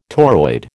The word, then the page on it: toroid: American English